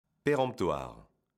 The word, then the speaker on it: péremptoire: Péremptoire. Aujourd'hui, notre mot du jour est péremptoire. Attention, le son en de pérant s'écrit E-M.